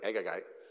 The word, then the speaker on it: Agagite: Haman, the Agagite, if you want to pronounce it correctly, Agagite, says, all right, I'm not only going to kill Mordecai, I want to kill all the Jews.